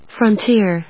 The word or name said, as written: frontier